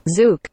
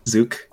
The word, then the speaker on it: Zouk: Zook. – Yeah. – Zook.